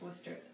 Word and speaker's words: Worcester: Without the shear at the end, it's pronounced Worcester, at least in Massachusetts.